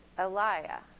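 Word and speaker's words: Eliyah: But in Aramaic, the word Elijah is pronounced Eliah.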